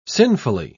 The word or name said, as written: sinfully